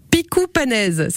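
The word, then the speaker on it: pikoù panez: Picoupanes, ça s'écrit P-I-K-O-U, avec mhm.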